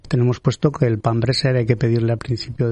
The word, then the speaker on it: pretzel: a ver si lo pronuncio bien, pretzel. – Pretzel, sí, eso es.